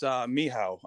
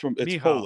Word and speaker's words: Michał: Hey, it's Michał. I know it's spelled a little differently, but it's Michał. Polish.